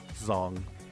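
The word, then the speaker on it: đồng: Zong. – Okay. – The Zong, although it's spelled with a D. The D dong. – Makes a Z.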